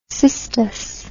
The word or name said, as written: cistus